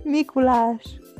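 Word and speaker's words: Mikulás: Because we call it Mikulash. Oh. That's how you say it in Hungarian. Who? How? – so she bougie. – how you say? Say it again. Mikulash.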